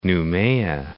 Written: Noumea

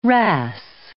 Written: wrasse

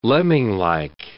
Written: lemminglike

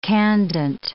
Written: candent

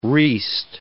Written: reest

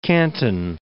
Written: Kanton Island

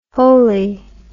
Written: wholely

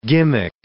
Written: gimmick

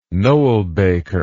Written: noelbaker

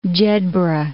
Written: Jedburgh

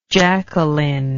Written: jacalyn

